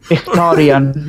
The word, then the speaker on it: Mkhitaryan: Mkhitaryan. Okay, Mkhitaryan, yeah.